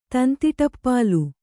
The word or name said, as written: ತಂತಿಟಪ್ಪಾಲು